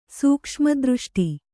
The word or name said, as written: ಸೂಕ್ಷ್ಮದೃಷ್ಟಿ